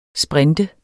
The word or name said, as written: sprinter